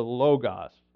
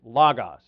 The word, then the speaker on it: logos: The Word in Greek is logos, L-O-G-O-S, which, you know, in the beginning was the Word in John 1:1. In the beginning was the logos, or logos, it's pronounced.